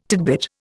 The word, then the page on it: tidbit: Indian